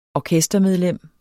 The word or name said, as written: orkestermedlem